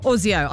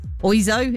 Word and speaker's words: Oizo: Mr. Ozio, I never really knew how to say it. Oizo? It's not Ozio, is it? It's O-I-Z-O.